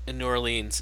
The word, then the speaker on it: New Orleans: Now, you live in New Orleans, and I probably pronounce it terribly, like a Canadian would.